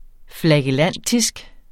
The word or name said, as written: flagellantisk